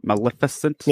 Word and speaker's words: Maleficent: Maleficent? – Yeah.